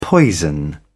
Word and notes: poison: British English

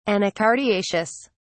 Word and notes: anacardiaceous: British English

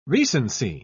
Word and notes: recency: British English

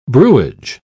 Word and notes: brewage: British English

